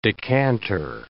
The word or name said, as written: decanter